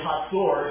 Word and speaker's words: Hazor: Don and I have visited these two in the north, Megiddo and Hazor, is how that's pronounced, like an H-A-T-Z-O-R is how the Hebrew sounds.